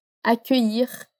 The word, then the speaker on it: accueillir: Mas pensa que a palavra accueillir, ela tem três partes: a, que, ir. Accueillir.